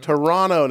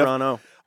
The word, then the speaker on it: Toronto: Sorry, I pronounced the second T. Mm-hmm. Toronto native. – The one native. Toronto.